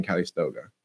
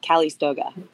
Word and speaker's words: Calistoga: Is that like, how far away is that from Calistoga, or is that in Calistoga? – I love how you're saying it. Calistoga. – Am I saying it wrong? – Calistoga, but you know, I liked your flair better, actually.